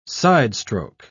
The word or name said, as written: sidestroke